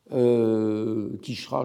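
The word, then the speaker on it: Quicherat: Quichera, je ne sais pas si j'avais son nom sur un des tableaux. non. Alors, Quichera, ça s'écrit Q-U-I-C-H-E.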